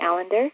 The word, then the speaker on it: Allender: Hello, my name is Katie Allender, and that is Allender rhymes with calendar, but it's not spelled like calendar because calendar ends with A-R and my last name ends with E-R.